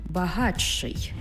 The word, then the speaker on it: багатший: Пишемо «багатший», але вимовляємо «багатший».